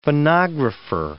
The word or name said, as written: phonographer